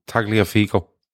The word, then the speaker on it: Tagliafico: Tagliafico.